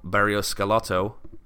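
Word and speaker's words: Barros Schelotto: But you know, reports of Guillermo Barrios-Skelotto.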